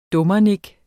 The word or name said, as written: dummernik